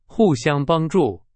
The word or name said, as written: 互相